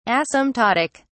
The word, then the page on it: asymptotic: British English